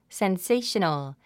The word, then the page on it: sensational: senséiʃənl; センセェショノゥ